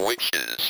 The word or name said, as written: witches